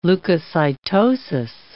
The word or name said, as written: leukocytosis